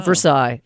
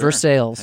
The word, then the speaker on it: Versailles: It's not Versailles. It's sure. – Versailles.